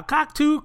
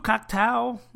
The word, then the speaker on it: Cocteau: Now, Cocteau, Cocktow, it's pronounced both ways in the uh, movie, depending on who's saying it.